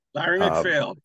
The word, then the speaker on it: MacPhail: Larry McPhail, yes.